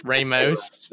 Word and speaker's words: Ramos: Ramos. That's for the middle America. – I get such a thrill hearing that R roll off your tongue like that.